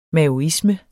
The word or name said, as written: maoisme